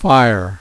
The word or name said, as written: fire